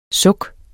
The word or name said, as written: suk